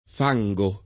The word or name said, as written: fango